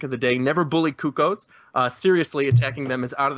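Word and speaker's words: Cucco: How do you say their name? Does anyone know? – I think it's Cuckoo. – One person at a time, please. – Kukos. – I believe it's pronounced Kukos.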